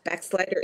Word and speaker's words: Bachslider: Backslider is spelled BACHSLIDER, like Johann Sebastian Bach.